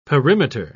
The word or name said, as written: perimeter